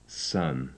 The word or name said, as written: son